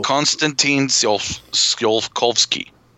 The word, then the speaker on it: Konstantin Tsiolkovsky: Konstantin Tsiolkovsky.